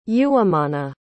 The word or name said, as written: uwimana